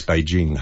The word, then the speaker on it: Beijing: And Brian Henderson used to say to me, Peter, it's Beijing, not Beijing. It's Beijing, hard J, and it's Los Angeles, not Los Angeles.